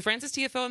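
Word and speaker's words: Tiafoe: it's Francis, is it TFO? Is that how you pronounce it? – Francis TFO. – TFO. Yeah. So Francis TFO and Ben Shelton played yesterday,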